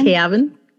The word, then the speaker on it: Kavan: Um, and his name, I don't know how to pronounce his name in real life because it's K A V A N, Kavan. Kavan, or just a fancy way of saying Gavin? Gavin.